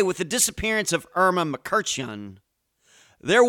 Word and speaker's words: Mkrtchyan: Irma Mkrtchyan, yep, that's how you pronounce her last name, came to the U.S.